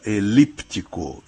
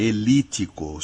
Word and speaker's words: elíptico: Bom, então, começando, esse P, quando a gente escreve, a gente pronuncia elíptico, elíptico. E quando a gente não escreve, a gente não pronuncia, é óbvio, ele não está lá, elítico. Só que esse caso elítico que existe, tem registro, está nos dicionários.